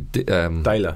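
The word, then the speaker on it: Deila: Daler, that's how you pronounce it, sorry.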